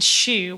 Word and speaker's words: Xu: I'm going to recommend Shu, which is it's spelt XU, and it's the new not so new now, opened about 5-6 months ago.